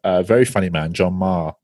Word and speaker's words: Meagher: We discuss how his name is spelt compared to how it sounds. – I've known John for years, and I've always said John Meager. – Yeah, it's spelt M-E-A-G-H-E-R. – John Marr. – So Irish spellings to pronunciations, mate.